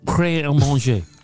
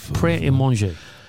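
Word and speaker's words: Prêt à Manger: Say Pret a Manger. Pret à manger. Say it. No. Pret à manger. No. The tea is silent. Pret à manger.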